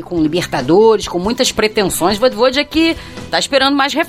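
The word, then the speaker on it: Vojvoda: Como é que é a pronúncia? – Vodvodja.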